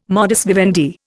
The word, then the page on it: modus vivendi: Indian